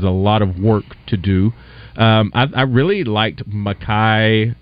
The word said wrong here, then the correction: Malachi: am I saying this right, Singleton? – Malachi Singleton.